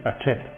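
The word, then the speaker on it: Hachette: ¿Cómo se dice eso en francés? – Sí, Hachet.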